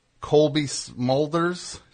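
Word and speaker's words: Cobie Smulders: And Colby Smulders. Is that how you say her name? – Yes, from How I Met Your Mother.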